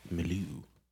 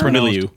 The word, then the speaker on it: milieu: Yeah, that I mispronounced. It's milieu. – Milieu, yeah.